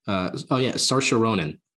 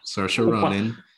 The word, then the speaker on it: Saoirse Ronan: Saoirse Ronan. – Interesting. – Saoirse Ronan.